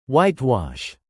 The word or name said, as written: whitewash